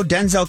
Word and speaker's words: Denzel: And on the show, Denzel corrected his host on how to pronounce his name. He said it sounds more like Denzel, not Denzel, Denzel, D-I-N-Z-I-L.